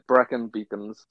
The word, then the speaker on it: Brecon Beacons: I remember trying to say that correctly. I'm like, Brecon Beacons. You're like, nah, man. You're saying it wrong. – Yeah. Yeah, Brecon Beacons.